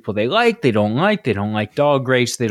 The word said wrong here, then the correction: Dogrates: But also, it's pronounced Dogrates. – Dogrates, right, right.